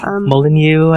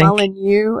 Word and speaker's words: Molyneux: Okay, another older bit of news that we should discuss is Peter Molyneux, and I'm probably pronouncing it wrong. – Molyneux, I think. – Molyneux, oh, it's French, Molyneux? – Yes, French-Canadian, I think.